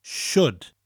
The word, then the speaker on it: shwd: In South Wales, we just pronounce the word sut differently. We pronounce it as shwd.